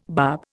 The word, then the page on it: bub: Indian